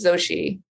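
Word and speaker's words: Xochitl: I want to say it's Octil Gomez. Oh, it's Zoshi.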